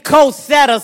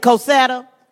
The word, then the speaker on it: Cosetta: Say Cosetta. No, don't say it like we're in the North. Say it like you just had some cornbread down in Carolina. Bring it up from the gut.